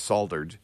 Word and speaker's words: solder: In the States, we say solder, and everybody gets mad at me in the rest of the world for mispronouncing it. There is an L in there, so I understand.